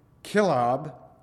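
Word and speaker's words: Chileab: And unto David were sons born in Hebron, and his first was Amnon of Ahinoam, the Jezreelitess, and his second, Kilab, that's really how you say it, I guess. That's what they tell me anyway,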